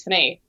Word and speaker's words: Fanny: So they changed it to A, which would have made it Fana, but then the Aussies couldn't pronounce that, so they made it Fanny.